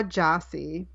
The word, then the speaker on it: Gyasi: So can you say it one more time? – Yeah, Jossie. – Ya Jossi. Okay. – Like the way I wrote it down, so I would pronounce it correctly, was YAJACI. Yeah, Jossie. But that, I mean, I'm just saying for phonetics, that's not, it's spelled GYASI, you Okay.